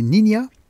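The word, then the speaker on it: Niña: Elle adopte donc ce nom de scène, Nina, qui signifie « petite fille » en espagnol, prononcé Nina, et Simone, en référence à l'actrice française Simone Signoret, son idole.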